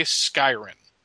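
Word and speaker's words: Skyrn: Apparently, the ice creature is inhabited by a life form from the planet, I'm going to say Skyrin. It's spelled S-K-Y-R-N. I keep saying I instead of Y.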